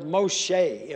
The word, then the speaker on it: Moshe: And you turn there, and it's the word Moshe, M-O-S-H-E is pronounced like an A, Moshe, Moses.